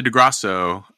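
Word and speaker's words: Di Grasso: The Cameron and Ethan or the DeGrassi? Is that how you say it? – The DeGrasso.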